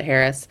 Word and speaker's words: Harris: Personally, I am very excited about Kamala Harris. Oh my gosh. Sorry. I mispronounced it. Harris.